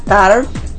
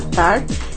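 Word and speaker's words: Tár: TAR, con mayúsculas y acento en la A, TAR.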